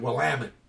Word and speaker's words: Willamette: It's spelled W-I-L-M-E-T-T-E. No A in it. – Ah, because they pronounce different, right? Willamette. – Yeah, this one comes from the French O-U-I-L-M-E-T-T-E.